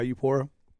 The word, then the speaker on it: Eupora: There's one other jerky maker in Mississippi, and he's like in, I don't even know if I'm saying this right. I think it's Eupora. – Yeah, Eupora.